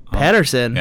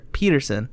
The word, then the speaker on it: Petterson: In case you're wondering who this sultry-voiced man is that is sitting next to me, his name is Jason Petterson. – Patterson. – Patterson. – Yeah, Patterson. That's how you say it? – Yeah. – Oh, I always thought it was pronounced Peterson.